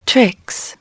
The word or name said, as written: tricks